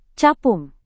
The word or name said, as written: capung